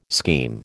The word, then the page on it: scheme: American English